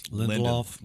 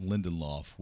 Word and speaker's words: Lindelof: Lindelof. – Lindelof was the showrunner on that.